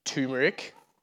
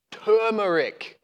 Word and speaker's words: turmeric: That is not turmeric or turmeric. It is turmeric. It has an R in it, friends.